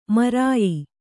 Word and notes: ಮೈರೇಯ: mairēya

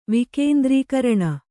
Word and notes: ವಿಕೇಂದ್ರೀಕರಣ: vkēndrīkaraṇa

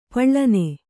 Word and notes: ಪಳ್ಳನೆ: paḷḷane

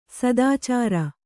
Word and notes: ಸದಾಚಾರ: sadācāra

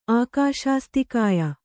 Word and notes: ಆಕಾಶಸ್ಥಕಾಯ: Kannada

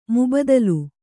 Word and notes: ಮುಬಾದಿಲೆ: mubādile

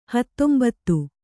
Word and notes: ಹತ್ತೊಂಬತ್ತು: Kannada

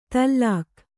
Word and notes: ತಲ್ಲಾಕ್: tallāk

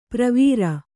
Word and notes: ಪ್ರವೀರ: kannada

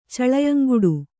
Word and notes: ಚಳೆಯಂಗುಡು: caḷeyaŋguḍu

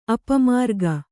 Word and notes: ಅಪಮಾರ್ಗ: apamārga

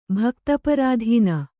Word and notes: ಭಕ್ತಪರಾಧೀನ: bhakta parādhīna

